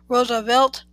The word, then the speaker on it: Roosevelt: His last name is, according to Roosevelt himself, pronounced as if it was spelled Roosevelt. That is, in three syllables, the first syllable as if it was rose.